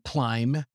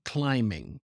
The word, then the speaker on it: climb: She said, for example, climb, climbing. And remember that with the word climb, we don't pronounce the final B. That B is silent, even if you use the ing form. So she said climbing.